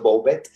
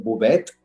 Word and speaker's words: Bouvet: Entonces de alguna forma no está mal dicho, Bouvet, es tal como se escribe. La pronunciación francesa es Bouvet, pero está bien dicho de las dos formas.